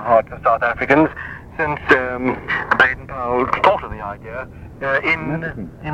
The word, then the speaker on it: Baden-Powell: And you won't hate me for this, but the family are quite particular about it. It's pronounced Baden-Powell.